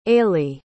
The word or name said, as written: ailey